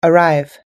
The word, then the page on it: arrive: us-english